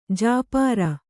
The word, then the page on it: ಜುಪರಿ: jupari